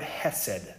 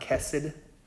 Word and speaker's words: hesed: It's this word hesed. Are you familiar with that? Anyone heard that word before? Hesed? Sometimes it's pronounced kesed. Right? It's a Hebrew word.